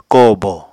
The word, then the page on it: kóò bo: Apatani